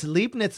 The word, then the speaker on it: Leibniz: Oh, it's Leibniz. That's how it's pronounced.